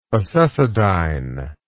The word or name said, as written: acesodyne